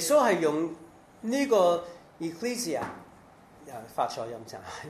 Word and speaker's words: Ecclesia: 那其实教会这个字 Ecclesia 上去 Ecclesia， OK，準確發音，多謝。